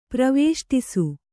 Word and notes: ಪರಿವೇಷ್ಟಿಸು: pari vēṣṭisu

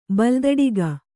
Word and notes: ಬಲುದಡಿಗ: Kannada

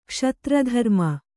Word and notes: ಕ್ಷತ್ರಧರ್ಮ: kṣatra dharma

